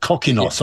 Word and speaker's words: Kokkinos: Kokkinos, yeah. I think is the pronunciation.